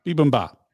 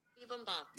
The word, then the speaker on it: bibimbap: I went to the Googles. Let's see what they say. Bibimbap. Bibimbap. Bibimbap. You got it. You nailed it. Bibimbap.